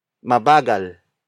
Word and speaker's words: mabagal: Mabagal kumain si Chris.